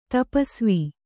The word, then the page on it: ತಪಸ್ವಿ: tapasvi